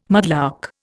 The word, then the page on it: mudlark: Indian